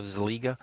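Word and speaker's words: Szeliga: The band recently announced a drummer change with former Black Label Society drummer Chad Zeliga, I guess is how you say it. It's S-Z-E-L-I-G-A, so Zeliga, joining the band.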